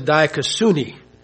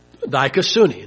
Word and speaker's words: dikaiosune: Now, from Thayers in the New Testament, Greek, the word is dikaiosune. Dikaiosune, and I'll spell it for you. It means it's spelled D-I-K-A-I-O-S-U-N-E. D-I-K-A-I-O-S-U-N-E. Dikaiosune.